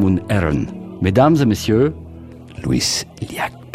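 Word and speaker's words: Lluís Llach: Vous pouvez prononcer euh, le nom Il s'appelle Louis Yac, de l a c h Louis Liac.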